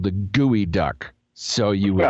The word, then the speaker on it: geoduck: So, you know, okay, gooey duck, who knows why it's called a gooey duck. But it turns out that it's spelled G-E-O-D-U-C-K, geoduck. That makes no sense.